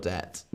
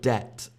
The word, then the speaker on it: debt: I am forever in your debt. No, it's a bit too dramatic. Tina asks, can I pronounce the word debt again? Debt. Debt. So we don't say the B.